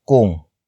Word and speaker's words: kung: After that, we have kung. Kung. Kung. Kung is spelled as K-U-N-G.